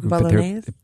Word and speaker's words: Bolognese: I'm not pronouncing that right. – Bolognese? – There you go.